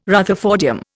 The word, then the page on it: rutherfordium: Indian